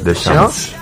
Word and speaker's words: Deschamps: pero Deschamps. – Deschamps.